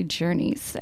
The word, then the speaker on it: Ger-Nis: So I've been mispronouncing the center as Gurney's. It's actually Jurnee's.